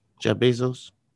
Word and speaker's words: Bezos: Bezos? Jeff Bezos. Am I saying it right? – Yeah, you saying it right.